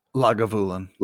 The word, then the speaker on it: Lagavulin: And then one of our other guys spoke up and he said, Langevulen. How do you say it? I can't say the word. – Lagavulin. – Lagavulin. Lagavulin.